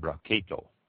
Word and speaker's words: Brachetto: Barchetto is spelled B-R-A-C-H-E-T-T-O. Pronounce it Barchetto.